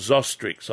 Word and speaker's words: Zostrix: Now, there's a little product called Zostrix. I'll spell it for you. – Yep. – Z-O-S-T-R-I-X, Zostrix. – Z-O-S-T-R-I-X, Zostrix.